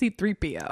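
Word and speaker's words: C-3PO: That's what it owes, so then maybe it's They're all CP30. Generation CP30. – I think it's C3PO. – Oh, damn, I always say it wrong.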